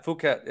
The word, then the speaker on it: Phuket: The Phuket Islands. – Yeah, yeah, Phuket.